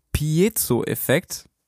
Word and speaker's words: Piezo-Effekt: Genau, Pierre ist genauer gesagt in der industriellen Physik ziemlich weit vorne mit dabei und er entdeckt den Piezo-Effekt.